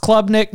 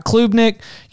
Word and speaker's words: Klubnik: I believe Klubnik is pronounced Klubnik, not Klubnik.